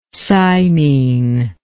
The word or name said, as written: cymene